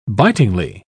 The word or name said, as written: bitingly